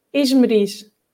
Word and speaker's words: Esmeriz: Okay, my name is hard to pronounce even for Portuguese people because it's not a common name at all. And we say Eshmirash.